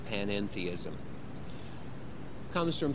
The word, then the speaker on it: panentheism: And the middle syllable, en, is very important because it differentiates it from pantheism, with which this notion is sometimes confused and wrongly identified. Let me explain what panentheism is in two different ways.